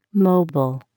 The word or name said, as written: mobile